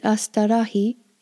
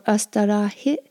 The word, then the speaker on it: astârâhi: A-sta-ra-hi. Or hit. Sometimes when that I ends a word, it sounds more like E, like a long I than a short I. We hear it both ways, astarahi or astarahit. So don't get too worried about that. People pronounce them a little bit different ways.